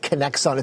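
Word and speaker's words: Kinexon: So the company, the word spelled with a K and an X, right? It's Connection. You'd probably say it like Connexon.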